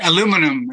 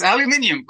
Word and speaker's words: aluminium: Aluminum is how you say this word. It's aluminium, guys.